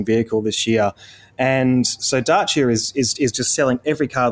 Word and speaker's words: Dacia: Never known how to pronounce that. You might correct me. Is it Dacia? – Dacia.